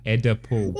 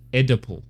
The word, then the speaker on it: Oedipal: She's been reading the book. She has to have read it by now and understood how it's spelled is not edible. – Well, I think she's just missed, like, because she's only seen it on paper. She doesn't know how it sounds. So she thinks that it's just pronounced edible.